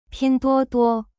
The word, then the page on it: 拼多多: pin duo duo